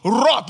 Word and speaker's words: wrought: Somebody say that word with me. Which he wrought. Let's spell that word together. W-R-O-U-G-H-T.